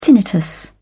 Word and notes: tinnitus: British English

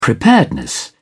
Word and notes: preparedness: British English

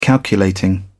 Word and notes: calculating: British English

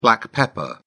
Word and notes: black pepper: British English

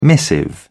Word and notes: missive: British English

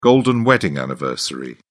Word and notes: golden wedding anniversary: British English

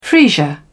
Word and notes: freesia: British English

